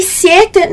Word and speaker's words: siete: Então, voi siete não tem muita dificuldade na pronúncia, porque é quase como se fosse o nosso sete, né? O número sete com um izinho ali no meio. Então, vira siete, ok?